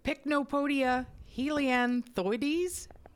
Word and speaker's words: Pycnopodia helianthoides: So Pycnopodia helianthoides? Is that it? Is that how you say it? Okay. – Yeah.